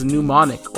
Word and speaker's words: mnemonic: is mnemonic or a mnemonic device, and the M is silent there.